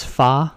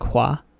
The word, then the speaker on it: Hua: In this Disney film, it's Fa, like F-A in English. I looked up, there's a more correct pronunciation that they will use in the 2020 film. I think it's like Hua. It's H-U-A.